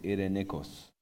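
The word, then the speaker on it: Irenikos (εἰρηνικός): So the word is, that's how I pronounce it, Irenikos.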